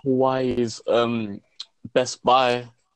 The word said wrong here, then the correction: Huawei: Well, you pronounce it as Huawei. That's how you pronounce it, is Huawei.